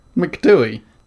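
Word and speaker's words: Ben Macdui: McDooey? – Yeah. M-A-C-D-H-U-Y.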